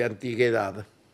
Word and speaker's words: antigüedad: Además, la mayoría de estos artefactos tienen siglos de antigüedad. – Antigüedad. Cuando tiene diéresis se pronuncia de güey.